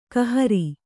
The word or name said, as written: ಕೈಹಾರೆ